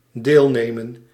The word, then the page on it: partake: American English; /pɑɹˈteɪk/